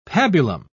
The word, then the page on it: pabulum: British English